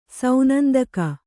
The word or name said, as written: ಸೌನಂದಕ